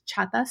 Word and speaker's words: Chata: I am very active on Twitter, and that's my Twitter handle is Chata Sweetie, which is spelled C H A T A S W E E T I E.